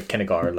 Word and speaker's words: Kinnegar: So the Kinnegar Limeburner Peel Eel, which I mispronounced as Finnegal, the political eel.